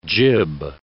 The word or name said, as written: jib